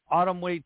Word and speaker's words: Atomweight: Ayaka Miyao scored a majority decision over Eri Matsuda in a 10-rounder to capture the vacant IBF Atomweight title.